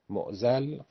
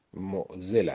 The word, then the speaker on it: معضل: الاعضالات العویسه، اعضال بازاد به معنای اشکال، معضل به معنای مشکل، تو فارسی معمولا تلفظ میکنن معضل. معضل غلطه، تلفظ درستش معضل است، همونطور که مشکل ما تلفظ میکنیم، مشکل نمیگوییم.